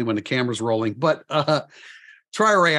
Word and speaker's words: Traoré: Boubacar Traoré, I've been saying Traoré this whole time, and I see Notre Dame's pronunciation is Traoré, and I think it's going to take us all a little bit of time to learn that and commit that to memory.